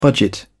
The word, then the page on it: budget: British English